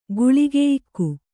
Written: ಗುಳಿಗೆಯಿಕ್ಕು

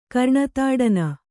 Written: ಕರ್ಣತಾಡನ

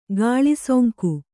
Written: ಗಾಳಿಸೊಂಕು

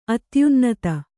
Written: ಅತ್ಯುನ್ನತ

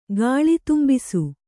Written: ಗಾಳಿತುಂಬಿಸು

